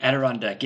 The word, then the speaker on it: Adirondack: Did I say that right? Adirondack? I always mess it up. – Adirondack, yeah.